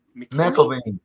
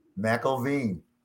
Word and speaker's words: McIlveen: Did I say that right, Nelson? – McElveen. Close enough. – What? McElveen? – McElveen. McElveen.